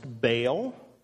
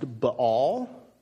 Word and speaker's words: Baal: You could have heard it pronounced Baal. I think the real technical meaning of it or pronunciation is something along the lines of Baal. So, lest you sit there and fret over a wrong pronunciation, I am aware that it's said in different ways.